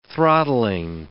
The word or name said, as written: throttling